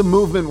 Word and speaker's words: MVMT: This is the Movement Watch ad. It's good to have them back. We haven't done this in a while. Movement Watches, and the code for it is MVMT. It's pronounced movement, obviously.